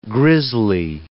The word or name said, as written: grisly